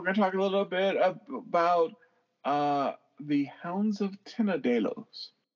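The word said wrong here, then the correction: Tindalos: I guess it's Tendalos. I always mispronounce it.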